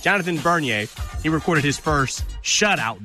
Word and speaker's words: Bernier: Backup goalie, Jonathan Bernier. Am I saying that right? I nailed it, Jack. – I'm so proud of you. We didn't even put a name pronunciation on there, and you still nailed it.